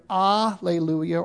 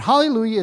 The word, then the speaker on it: hallelujah: Often in English, you will see the H omitted to follow that Greek transliteration. In fact, that's how it is in our English Bible here, because that's what the Greek did. It left that H off. So it's either alleluia or hallelujah. And hallelujah is actually the Hebrew pronunciation.